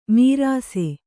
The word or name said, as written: ಮೀರಾಸೆ